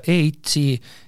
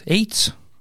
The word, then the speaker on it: E-ITS: kas te oskate mulle välja tuua ISKE ja EITS-i, kuidas me üldse hääldama peaks, EITS? me niimoodi omakeskis hellitavalt ütleme eitsuke või eits, eitsu käänates, aga ametlikult viisakalt öeldakse eits, eitsi.